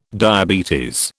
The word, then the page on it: diabetes: British English